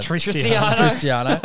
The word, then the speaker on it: Cristiano: As a Cristiano.